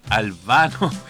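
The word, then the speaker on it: Al Bano: Al baño. No, al vano. Al baño. No, al baño no, Albano y Romina Paz. Oye, y esta canción era como así. – Uy. – Eran una pareja perfecta. Albano, o sea, Albano y Romina Power.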